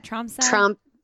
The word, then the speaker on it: Tromsø: What, the Tromsø? How do you say that? – Tromsø sky race.